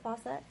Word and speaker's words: Fawcett: I just think it's interesting, you know, director and producer John Fawcett, am I saying that right, Fawcett? – Yes.